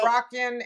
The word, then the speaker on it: Moroccan: How do you say? Is it like Morocco? – Moroccan and Monroe.